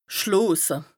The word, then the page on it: schloose: Bas-Rhin; Strasbourg